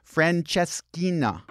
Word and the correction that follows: Francesinha: Francesinha. – Francesinha.